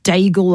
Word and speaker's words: Daigle: No, and I mean, it's not spelled Daigle like a bagel. It is spelt D-A-I-G-L-E.